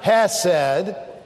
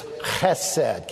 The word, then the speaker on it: chesed: Now, you got to say it like a Hebrew, not like an American, okay? It's not chesed. It's got a little guttural sound to it, kind of a little hockey sound to it. It's chesed.